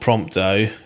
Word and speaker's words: Prompto: So you've got one for each of your other members, like Gladius, Old Iggy, and Old Prompto. Is it Prompto? – Yeah. – In fact, that's how you pronounce it now.